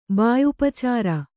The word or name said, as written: ಬಾಯುಪಚಾರ